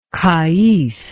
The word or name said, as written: qaid